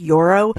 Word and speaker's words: Joro: These huge, they're called Yoro spiders, J-O-R-O, but I think it's pronounced Yoro.